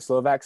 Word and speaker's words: Slovak: The American interiors comes first, but the whole Slovak, am I saying that right? Slovak section, Slovak, yeah.